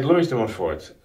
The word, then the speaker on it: Louis de Montfort: St. Louis de Montfort, that's the way in English we would say his name,